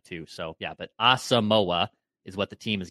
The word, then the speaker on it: Asamoah: So they put pronunciations in here. – Correct. And Pro Football Reference also has it as Asamoah. But people, there's like a wave of people this week that are saying it's Asamoah. Brian Asamoah. Until the Vikings say otherwise, we're gonna go with their pronunciation, Asamoah.